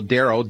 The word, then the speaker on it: Darryl: It's Daryl Prail. So Daryl, D-A-R-R-Y-L, Prail, Peter, R-A-I-L-L.